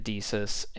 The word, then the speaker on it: DECUS: The game was submitted to DECUS, I believe it's pronounced. It's D-E-C-U-S, DECUS, which its programs often were a part of the initial games installed on DEC computers.